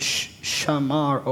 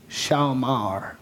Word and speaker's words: shamar: It's the Hebrew word shamar or shamar, however you wanna. It's spelled S H A. M-A-R or S-H-A-W, M-A-R.